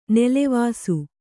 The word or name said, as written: ನೆಲವಾಸು